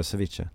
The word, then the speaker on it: ceviche: Precis, det är så man uttalar det, ceviche.